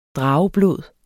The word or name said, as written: drageblod